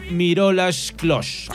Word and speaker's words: Miroslav Klose: Pero hasta el momento, como máximo goleador de todas las copas del planeta, de todas las copas del mundo, es el alemán Miroslav Klose.